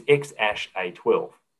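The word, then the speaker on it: X Æ A-12: And of course, his name is XASH-A12. That's how it's pronounced.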